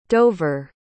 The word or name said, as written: dover